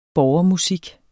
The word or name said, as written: borgermusik